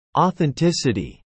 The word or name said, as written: authenticity